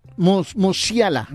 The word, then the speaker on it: Musiala: Musiala.